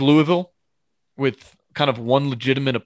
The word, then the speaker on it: Louisville: Okay, Louisville. Did I say it right? – Louisville, yeah.